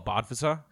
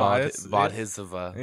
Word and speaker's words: Bodhisattva: And she was like, oh, a Bodhisattva? I was like, that's not how that's pronounced.